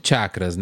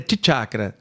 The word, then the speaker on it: chakra: A gente chegou a falar sobre chakras, né? É chakras ou é chakras? – É, tanto faz. A pronúncia certa é tichakra. – Tichakra. – Mas em português, como transcreve com C-H-A-C-R-A, todo mundo fala chakra.